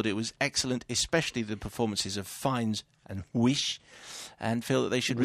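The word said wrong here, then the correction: Weisz: Is that how you pronounce it? Weiss, surely.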